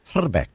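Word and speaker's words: Hrbek: I speak a little bit of Czech, and the actual correct pronunciation of his name is Herbeck. You've got to roll the I.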